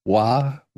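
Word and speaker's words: Voir: Dann guck dir bitte auf Netflix eine Doku-Serie namens Wah an. Produziert von David Fincher, unter anderem mit Tony. – Schreibt man das? – V-O-R. V-O-I-R. Wah. Mhm. Wie Noir, nur mit V.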